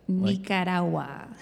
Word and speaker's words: Nicaragua: Nicaragua.